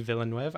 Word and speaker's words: Villeneuve: Uh, I think Denis Villeneuve.